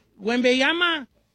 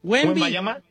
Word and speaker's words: Wembanyama: Wembanyama. – Wembanyama.